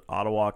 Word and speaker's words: Ottawa: We're doing Topeka, Wichita, and if I mispronounce it, Ottawa, Kansas. – I think you said it right.